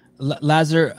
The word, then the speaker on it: Lazar: Lazar, are you going to try to go to the games next year? – Of course, yeah.